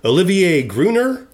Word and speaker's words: Olivier Gruner: Well, Nemesis also stars, and I hope I pronounce this right because I'm terrible at other languages, Olivier Gruner. – I believe that's how I heard it pronounced on the behind the scenes I watched.